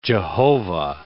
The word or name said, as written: Jehovah